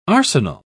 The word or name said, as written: arsenal